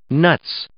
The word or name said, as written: nuts